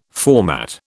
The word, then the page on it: format: British English